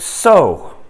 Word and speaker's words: sow: As you look at this slide, you see the word so, as in S-O-W. So what? Well, that same word could also be used and pronounced sow. I'm not trying to confuse you. And that's a mother pig, right?